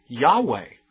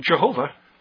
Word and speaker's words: Yahweh: That is why, by the way, that we don't really know for sure how to pronounce the Old Testament name of Jehovah. Most Bible scholars now prefer to pronounce that Yahweh. You say, that doesn't sound like Jehovah.